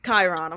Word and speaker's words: Chiron: She's like, you pronounce it wrong. I say Chiron, Chiron, all types of shit. – Oh, Chiron. – She's like, stop. It's Chiron.